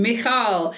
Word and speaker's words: Michal: Michael is, how do you say her name? – Michal. – I've always said Michael.